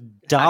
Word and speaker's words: DALL-E: Kind of like Salvador Dolly, but spelled like Wally with a D. So it's Dolly. – How do you spell it? – So D-A-L-L-E, Dolly.